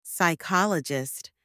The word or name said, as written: psychologist